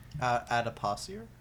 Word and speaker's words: adipocere: Is called what I believe is pronounced adipocere. – Adipocere? – No, adipocere.